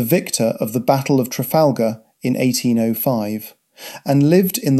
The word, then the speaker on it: Trafalgar: This was originally called Standlinch House, but since 1815 has been known as Trafalgar House, pronounced locally, as in Spanish, Trafalgar.